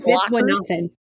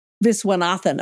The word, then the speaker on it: Viswanathan: And so Jamie decides to hit the road with her uptight intellectual friend, Marion, who is played by Geraldine Viswanathan. I hope I'm saying that right. – I think it's Viswanathan. – Viswanathan. Oh, I've always got the accent wrong.